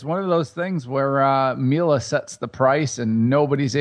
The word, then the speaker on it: Miele: So it's like, if you want to check it out, it's the brand is Miele. That's spelled M-I-E-L-E.